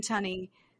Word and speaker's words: Tunney: Tunney? – I can't remember her Tunney? – Name. – I don't know how to say her last name. – Yeah, Robin Tunney.